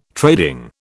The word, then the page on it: trading: British English